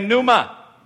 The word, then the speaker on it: pneuma: Someone say pneuma. The P is silent here, pneuma, and it means a current of air, a blast of breath, a strong breeze.